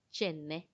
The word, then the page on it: chenne: Macuiltianguis